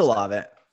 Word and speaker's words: Olave: It's Olave.